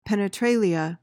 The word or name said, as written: penetralia